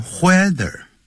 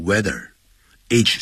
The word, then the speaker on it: whether: 那我们就在前面加一个 whether，这个不要念成 whether，要念成 weather，H 是不发音的。